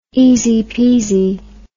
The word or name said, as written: easy peasy